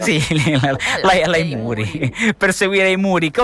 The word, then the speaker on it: IMURI: I Muri, com'è il nome? – I Muri.